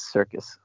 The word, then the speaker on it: Serkis: It's either Serkis or Circus, and I like Circus better. So Andy Serkis is currently in talks to play Alfred. What did you think? – Yeah, I think it is Circus. So at least that's how I always pronounce it.